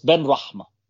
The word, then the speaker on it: Benrahma: So, no, it's Benrahma.